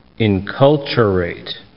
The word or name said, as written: enculturate